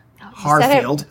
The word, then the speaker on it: Harfield: Harfield. – I said it wrong. I put the T in. There's no T. – No T in Harfield.